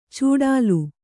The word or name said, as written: ಚೂಡಾಲು